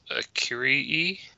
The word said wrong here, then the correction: Akakiri: Akakiri. – Akakiri. Okay. – No, and it's not... Kaka sounds appropriate, but Akakiri.